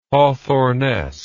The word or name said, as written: Hawthornesque